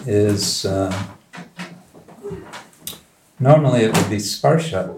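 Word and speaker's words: sparsha (स्पर्श): Normalnie byłoby to słowo sparsha, które oznacza dotyk. – But if the gopis just said sparsha, it would be one syllable too short.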